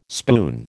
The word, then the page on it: spoon: American English